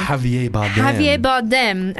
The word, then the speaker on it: Javier Bardem: Javier Bardem.